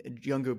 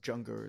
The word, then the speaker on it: Junger: Tribe is by Sebastian Younger, Younger but with a J. So Junger, it's phonetically pronounced.